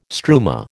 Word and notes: struma: American English

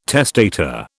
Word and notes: testator: British English

